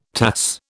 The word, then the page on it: Tass: British English